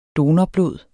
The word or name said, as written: donorblod